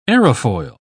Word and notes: aerofoil: British English

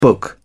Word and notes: book: British English

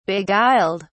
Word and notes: beguiled: British English

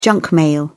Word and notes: junk mail: British English

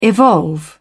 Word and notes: evolve: British English

